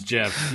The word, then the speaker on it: Jif: But it's pronounced Gif. – No, certainly that one certainly is not. With a J. – I know. I'm also, just kidding. I pronounce it's Jif too.